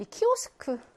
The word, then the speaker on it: Kiosk: 바로 앞에요라고 했는데 이 키오스크 발음 먼저 보자. S라는 자음이요. 뒤에 자음 앞에 있잖아. 자음 앞에 있으면요. 그냥 스로 발음합니다. 뭐, 키오스크, 우리도 이렇게 발음하죠.